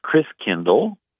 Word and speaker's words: Christkindl: And the German pronunciation of Christ child is Christkindl, like kindergarten, kindercare, kind means child.